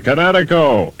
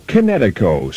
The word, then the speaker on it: Kinetico: Kinetic Energy with an O on the end. Kinetico. – Kinetico. Spell it K-I-N-E-T-I-C-O.